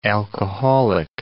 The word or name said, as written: alcoholic